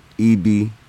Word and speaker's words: Ibe: It's King E.B. Bello. It's almost like the letter E and B, but it's spelled I-B-E. King okay. E.B. Bello.